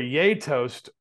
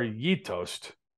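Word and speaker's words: Gjetost: Getost cheese, pronounced, well, now it says, it's pronounced either yay toast or ye toast by Scandinavians, is known as a Brunost cheese.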